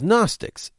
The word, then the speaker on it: Gnostics: They called themselves Gnostics, spelled with a silent G, like Gary Gnu.